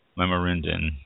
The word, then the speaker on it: Lamorinda: And then the third one that is being proposed is the Lamorinda viticulture area, L-A-M-O-R-I-N-D-A, Lamorinda, I believe it's pronounced.